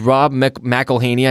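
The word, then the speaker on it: Rob McElhenney: It's Rob McElhaney. I never know how to say his name. – I think you nailed it.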